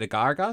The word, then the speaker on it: agar-agar: as well as I see it as like is it agar-agar? Is how you pronounce it? – Yeah.